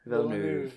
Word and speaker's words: Villeneuve: How do we pronounce it? Like Villeneuve? – Villeneuve, that's it.